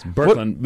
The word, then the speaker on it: Birkeland: Yeah, that's close enough. Birkeland. But listen, we're not in the old country of Norway, so Birkeland is the Norwegian way to say it, Skandi.